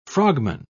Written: frogman

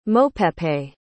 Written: mopepe

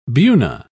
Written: buna

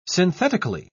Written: synthetically

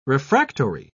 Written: refractory

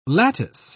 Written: lattice

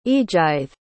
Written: eadgyth

